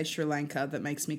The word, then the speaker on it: Sri Lanka: How many water cooler conversations do you think happened after the curry episode of people going, it's Sri Lanka.